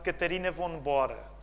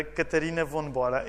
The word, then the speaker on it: Catarina von Bora: A senhora com quem ele casou chamava-se, eu estou a dizer à portuguesa, não estou a fazer a pronúncia alemã, mas chamava-se Catarina von Bora. Okay? Catarina von Bora.